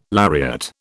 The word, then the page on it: lariat: British English